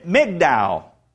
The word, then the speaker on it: Migdal: It's the modern town is pronounced Migdal.